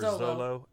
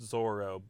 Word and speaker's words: Zoro: So, for instance, like, the first crewmate he meets is this swordsman named Zoro, or Zolo, as it's known in the manga. It's pronounced Zoro, but it's the sort of, like, Japanese to English translation thing where the L and the R pronunciation is transposed.